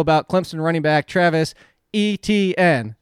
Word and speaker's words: Etienne: How you pronounce his last name is just like the letters E-T-N. Etienne. – That's dumb. – And hold on, it's not even French. It's actually technically Cajun Louisiana, which I know is French descent, but... It's actually, if you pronounced it from there, it would actually be Achen.